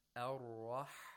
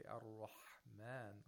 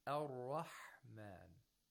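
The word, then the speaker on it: Ar-Rahman (الرحمن): Ar-Rahman. Bismillahirrahmanirrahim. If you say Bismillahirrahmanirrahim with too much buha, it gets close to the pronunciation of kha. And we don't want that. That would be incorrect.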